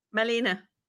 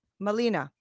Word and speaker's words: Malene: Uh, and now is it uh, Maylene or Marlene? How do you say your first name? Malina. Malina. Yeah.